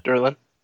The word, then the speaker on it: Durlan: girlfriend is a Durlan, yeah? Is that how you say it? – Yeah, uh-huh, yeah. – Durlan.